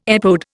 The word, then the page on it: epode: Indian